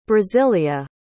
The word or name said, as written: brasilia